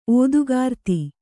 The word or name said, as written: ಓದುಗಾರ್ತಿ